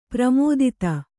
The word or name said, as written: ಪ್ರಮೋದೂತ